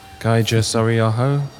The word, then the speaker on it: Kaija Saariaho: So next we have a piece by, I'm going to say this right, Kaija Saariaho, Finnish composer based in Paris.